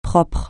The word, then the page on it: propre: francais